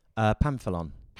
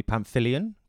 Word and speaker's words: Pamphilon: How is it pronounced? – Pamphilon. – Pamphilon. – Yeah. – Oh, okay. Literally as it's spelled. – But no one ever gets it right. So people call me Pamphilion because there's an I-L-O. No, hang on. What is it? It's P-I-L-O.